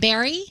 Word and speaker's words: Wilkes-Barre: Wilkes-Barre? – Barry? – Yeah. Yes. – No, it's B-A-R-R-E. Yeah. Is that how you say it? – Yes, that's exactly how you say it.